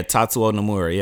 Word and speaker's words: Tatsuo Nomura: That's how you pronounce his name? – Yeah, Tatsuo Nomura.